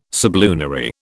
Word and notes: sublunary: British English

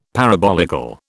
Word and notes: parabolical: British English

